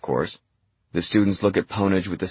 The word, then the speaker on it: Pwnage: Pwnage, Samuel says. Two syllables, rhymes with onage, but with a P.